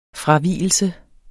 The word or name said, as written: fravigelse